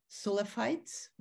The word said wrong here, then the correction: Salafis: right? How you pronounce it? – Salafis, exactly. They're Salafis.